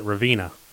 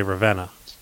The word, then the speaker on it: Ravenna: Up north, we, we, we pronounce it Ravina. You Southern boys say Ravenna.